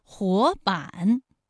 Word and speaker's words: 活版: 活版，第一个字是二声，第二个字是三声。